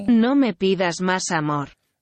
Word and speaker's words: No Me Pidas Más Amor: And this song is called No Me Pidas Más Amor. I think I've pronounced that correctly. – No Me Pidas Más Amor. – Oh, yeah. There you go.